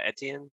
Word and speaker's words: Etienne: And then the dude from Jacksonville, Etienne, I don't know how to fucking pronounce his name. – Oh, Etienne? – Yeah.